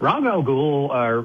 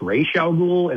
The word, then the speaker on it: Ra's al Ghul: But Rajah Gul, and that's the other thing. In the movie, they call him Rajah Gul. In the comic book, it's pronounced Ra's al Ghul, and like Batman fanboys will jump on you.